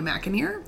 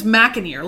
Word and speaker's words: McAnear: Welcome to Vintage Picking with Bad Madge, and I'm Tanya McAneer, and my last name is McAneer, like a Big Mac in your ear.